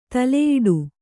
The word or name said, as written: ತಲೆಯುಡೆ